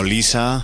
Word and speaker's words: Leshurr: Desde las islas británicas, una mujer llamada Lady Lisa, que por cierto, aunque se pronuncia así rollo Lisa, Lady Lisa se escribe tal cual, Lady, L-A-D-Y, y luego lo de Lisa es un poco más especialito, L-E-S-H-U-R-R.